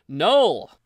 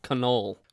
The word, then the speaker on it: Knull: Knull. Yes. Or as some folks pronounce it, Knoll.